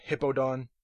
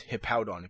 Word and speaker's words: Hippowdon: I've heard Hippodon and Hippodown, but it's Hippowdon. If you look at the spelling of the name, you can tell exactly how to pronounce it.